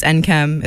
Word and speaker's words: Nkem: So my name is NKM. It's spelled N-K-E-M.